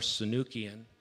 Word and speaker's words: Sunukjian: Sunukjian, and it's spelled like it sounds, Sunukjian. it's S U N U K J I A N.